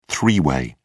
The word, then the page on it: three-way: British English